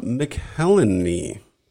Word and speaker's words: McElhenney: So October 1st, 2008, Caitlin Olsen weds Rob McElhenney.